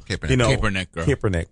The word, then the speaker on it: Kaepernick: So what do you think, your opinion on this, counterpointing it, is Colin Kaepernick, who is doing peaceful, Kaepernick. – I'm sorry. Kaepernick. Did you just correct my pronunciation? – I mean, that's his last name.